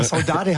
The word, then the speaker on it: Saudade: Ja, aber Saudade hast du so gut ausgesprochen, das ist nämlich Portugiesisch.